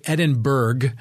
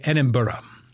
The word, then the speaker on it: Edinburgh: And then we worked our way to, I guess Americans would say Edinburgh, because that's the way it's spelled, like Pittsburgh. But the locals say Edinburgh.